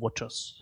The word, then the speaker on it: watchOS: Die richtige Aussprache ist WatchOS.